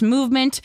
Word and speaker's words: MVMT: It's spelled MVMT, but it's pronounced Movement.